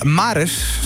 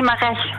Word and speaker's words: Mares: Je gaat spelen tegen Maris. Zeg ik dat goed zo? – Nee, Mares. – Mares is het, oké.